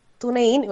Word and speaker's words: TuneIn: TuneIn, lo he dicho bien, ¿no? – Sí, sí, por eso increíblemente.